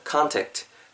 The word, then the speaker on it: contact: 콘택트라고 발음하시기보다는 기왕이면 컨택트, 컨택트 이렇게 발음해 주시고요.